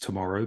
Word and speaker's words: TMRW: pronounced tomorrow, but TMRW, digital bank in Thailand and Indonesia.